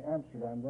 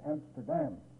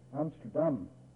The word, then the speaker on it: Amsterdam: We usually say Amsterdam. They always put the accent on the last syllable, and it's Amsterdam. Amsterdam.